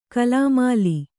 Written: ಗಾಲ್ಮೇಲು

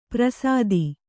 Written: ಪರಸತಿ

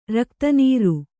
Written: ರಕ್ತನೀರು

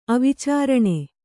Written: ಅವಿಚಾರಣೆ

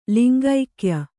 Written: ಲಿಂಗೈಕ್ಯ

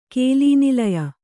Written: ಕೇಲೀನಿಲಯ

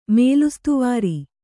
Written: ಮೇಲುಸ್ತುವಾರಿ

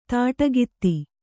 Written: ದಾಟುಕಥೆ

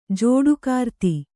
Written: ಜೋಡುಕಾರ್ತಿ

